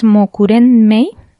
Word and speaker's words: Mokurenmei: mi nombre es Mokurenmei. En Instagram se escribe M-O-K-U-R-E-N-M-E-I. Lo repito de nuevo, M-O-K-U-R-E-N-M-E-I.